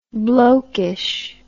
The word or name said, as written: blokeish